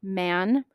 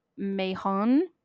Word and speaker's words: Mahon: Some people pronounce it Man. Some people pronounce it Mahon or Mahoon. I'll probably just pronounce it Man. It is spelled M-A-H-O-N.